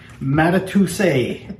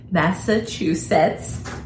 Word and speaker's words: Massachusetts: Matatuse. – Massachusetts.